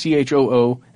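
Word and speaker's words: Choi Soon-sil: So President Park had a very close friend, a woman named Choo Soo-sil. That's what we're going to pronounce it as, but it's Choo Soo-sil. – How else would you pronounce that? – That's how I would do it.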